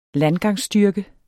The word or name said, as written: landgangsstyrke